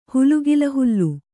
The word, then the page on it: ಹುಲುಗಿಲಹುಲ್ಲು: hulugila hullu